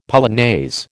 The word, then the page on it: polonaise: American English